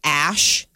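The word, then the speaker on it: Æ: Are speculating the AE is a character in Scandinavian, the alphabet there, and it's pronounced Ash.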